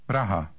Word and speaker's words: Praha: Praha. Praha. – Now you say Prague. – Praha.